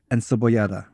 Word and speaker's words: encebollada: Carne frita, and let me see if I can pronounce this correctly. Encebollada. – Yes, you said it right.